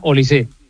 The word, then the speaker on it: Olise: No, es francés. Es francés. No lleva acento, pero se pronuncia Olise.